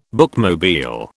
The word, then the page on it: bookmobile: British English